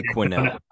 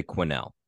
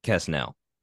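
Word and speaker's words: Quesnell: Quinnell. I would say it's a silent S. I would say Quinnell. – Okay. – Because I don't think it's Queso. I don't think it's Kesnell. I would say Quinnell.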